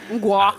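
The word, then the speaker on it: Mgła: Er det en gua, må jeg si? – Gua, gua. – Gua, altså M-G-L-A.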